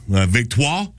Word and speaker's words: Victoire: and Victoire in Montreal, if I'm saying that correctly. – You are.